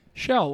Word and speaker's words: Chell: Chell, like Michelle. Like the, let's not do this again. – Like Michelle Obama, right? – Michelle Obama.